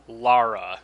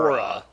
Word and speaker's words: Lara: Lara. – Lara. – Yes. Like Mara. Laura. Like Lara. – Right. – Yes. – Because, yeah, usually when there's no U, it is pronounced Lara, not yes. Laura.